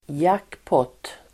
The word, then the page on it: jackpot(t): [²jak:påt:]